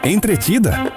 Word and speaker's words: entretida: Entretida ou entertida? – Como se pronuncia? – Quando uma pessoa está concentrada, né, está envolvida com o conteúdo, ela está ali dedicando a atenção dela, a gente fala que ela está entretida.